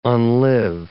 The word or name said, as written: unlive